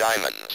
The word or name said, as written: diamonds